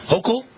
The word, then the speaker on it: Hochul: I'm not sure how you pronounce it. I actually have never heard her name pronounced. Hochul? Hochul, okay.